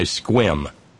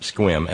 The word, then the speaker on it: Sequim: And that whole kerfuffle was ginned up by a Facebook message from Fred's Guns in nearby Squim, Washington. – Squim. – Yeah, Sequim, pronounced Squim.